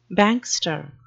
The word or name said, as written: bankster